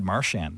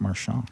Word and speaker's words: Marchand: Well, it's Brad Marchand now. It's not Marchand.